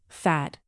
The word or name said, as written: fad